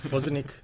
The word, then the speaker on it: Bozanic: Bozanic. – Bozanic? – Bozanic, sorry. Jesus. – Although some people don't pronounce it with a ch.